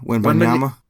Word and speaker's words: Wembanyama: How do you say his last name? Wembanyama? – Wembanyama.